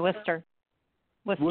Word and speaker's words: Worcester: It's Worcester College, right? Am I saying that right? – Worcester? Yeah, Worcester.